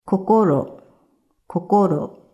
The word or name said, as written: Kokoro